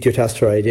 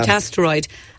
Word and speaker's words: dutasteride: Male in his 70s says he's been prescribed, pronunciation one, Dutasteride, yeah. – Dutasteride.